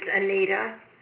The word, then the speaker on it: Annita: My first name is Anita, A-N-N-I-T-A. You want my last name, Zelensky, Z-A-L-E-N-S-I.